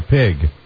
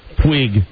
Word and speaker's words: Puig: It looks like Claudia Pig. It's Claudia Puig Quig. Well, no, it's P-U-I-G. How would you pronounce that, Jim? – Puig.